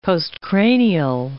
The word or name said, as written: postcranial